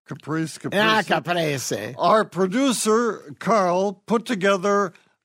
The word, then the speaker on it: caprese: Yes, I was listening to the Zorba pastor on your health show today, and he said it's caprese salad. It's not. It's caprese. Caprese is a Italian term for a tomato and mozzarella dressing. So just wanted to correct his pronunciation.